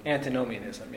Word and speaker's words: antinomianism: Antinomianism. – Antinomianism.